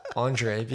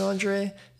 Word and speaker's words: Andriy: Andriy, because I don't look like an Andriy.